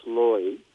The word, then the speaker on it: Leu: Is it L-E-U? – Yeah, actually, L-E-U, it's actually pronounced Loy, Freud or Reuters, and it's the old German word for Leo, the lion. – Mm. – Instead of being L-E-O, it's L-E-U in German.